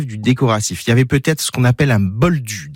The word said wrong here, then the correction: bolduc: derrière, un bolduc, ça s'écrit B-O-L-D-U-C.